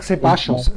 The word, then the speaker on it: Ceballos: Nein, ich sage Ceballos, Ceballos, sage ich. Doppel-L wird ja ein Sch ausgesprochen.